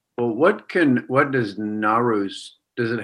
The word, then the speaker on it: Nowruz: And the pronunciation is like no, which is new. Ruz, and Ruz is day. So it's Nowruz, but the way we write it in English is Nowruz, Nowruz. – Nowruz.